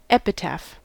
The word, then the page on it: epitaph: American English